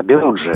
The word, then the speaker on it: Белуджи: Вот как раз о Белуджах мы сейчас и будем говорить, если я правильно поставил ударение. – Да, правильно, Белуджи.